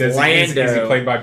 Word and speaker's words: Lando: You have weird ways of pronouncing these names. Londo, Leia. How do you pronounce it? Lando and Leia, like they're supposed to be.